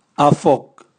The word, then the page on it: avok: Raga